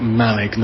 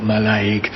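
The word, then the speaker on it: Mallaig: And then for place names with two syllables, it's difficult to say, really, but two syllables ending in ick or egg, generally it's the first syllable, like Malig, not Malaig,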